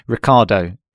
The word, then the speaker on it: Riccardo: Yeah, I thought I'd be flash and do the Italian pronunciation, Riccardo, for the English one.